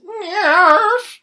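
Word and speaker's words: NIIRYF: You can find this very podcast itself on Twitter at NIIRYFPod, which is the letters for Not If I Reboot You First, and is pronounced NIRF.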